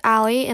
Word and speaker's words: Allie: Hey everyone, my name is Allie, and I'm Kelsey's younger sister. My name is spelled A-L-L-I-E.